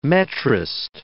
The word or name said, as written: metrist